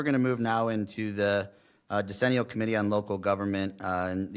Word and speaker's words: decennial: And I am going to turn it over to the township supervisor on the decennial, is that how you say it? – I looked it up and that is how you say it, yes.